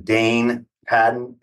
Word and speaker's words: Daine Patton: I'm very excited because I've got my man, Daine Patton, in the house.